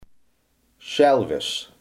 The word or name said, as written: schelvis